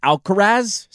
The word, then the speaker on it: Alcaraz: The last time Scotty Scheffler missed a cut, Sinner and Alcaraz, is that how you say it? – Yeah.